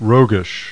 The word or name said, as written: ROGUISH